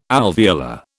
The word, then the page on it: alveolar: British English